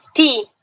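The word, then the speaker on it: ti (ты): Tú se pronuncia ti.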